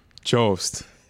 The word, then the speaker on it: Jost: And a young punk, up-and-comer, Tyson Yost. – Jost. – Oh, it's Jost? – Come on. – Really? I thought Jost. It was a silent Y. – Jost, yeah, most.